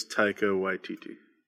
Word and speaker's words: Taika Waititi: I believe it's Taika Waititi.